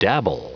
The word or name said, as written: dabble